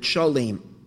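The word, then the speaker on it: Shalem: Now the second half of the word is Shalayim, which you would think is Shin Lamed Yud Mem, like it is spelled some places. Shin Lamed Yud Mem. Shem didn't call it Shalayim. He called it Shalim.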